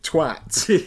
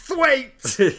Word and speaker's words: Twat: But then in Guest House Paradiso, he's called Richard Twat. Yeah. But then he says, it's pronounced Thwaite!